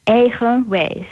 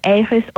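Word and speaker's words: eigen wijs: Is it Ehoways? – Ehoways. So Ehoways own in Dutch, German too, Ehoways. Ways is from wisdom, wise.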